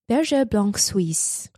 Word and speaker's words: Berger Blanc Suisse: Berger Blanc Suisse.